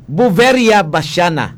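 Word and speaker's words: Beauveria bassiana: Ano to gani ang kwanto gani ang fungus basyahe? – Buveria basyana. – Aw oh, abi ko basyahe.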